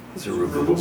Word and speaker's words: Zerubbabel: Before, I don't know how to pronounce this, Zerubbabel? – Zerubbabel. – Zerubbabel.